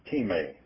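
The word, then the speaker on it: time (τιμή): The word price is the Greek word T-E-E-M-E, I think. I'm not exactly 100% sure. It's pronounced time. Actually, I think it's T-I-M-E. We use the word time, but it's time, and it means value, money paid.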